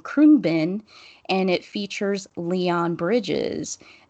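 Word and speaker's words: Khruangbin: So the name of the band is Krungbin. – Krungbin.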